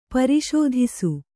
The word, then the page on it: ಪ್ರಸಾದಿಸು: prasādisu